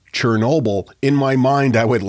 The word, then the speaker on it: Chernobyl: a number of years ago, I was asked to record the audio for a report about the disaster at Chernobyl, which is how I pronounced it and they kept sending it back to me saying, no, no, you need to pronounce it Chernobyl. Chernobyl is just the way that Americans say it.